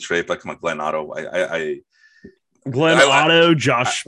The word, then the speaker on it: Otto: No, it's Glenn Otto. You say his name the same backwards and forwards, just the way he pitches, too.